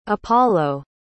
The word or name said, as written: appollo